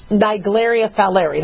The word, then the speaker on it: Naegleria fowleri: Some people call it Naegleria, Nigelaria. It's Naegleria fowleri. That's how I pronounce it.